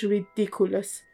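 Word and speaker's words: Riddikulus: این وردشه که مدل اسپلش فرق میکنه و تلفظش ریدیکولس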